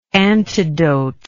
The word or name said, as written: antidotically